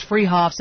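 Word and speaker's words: Fruehauf's: They're spelled Fruhoffs, pronounced Freehoffs, at 33rd and Arapaho in Boulder.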